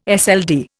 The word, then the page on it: SLD: Indian